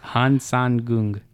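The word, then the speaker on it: Hang Sơn Đoòng: I think you said that. I don't think I pronounced that right. Say again. – Han San Gung.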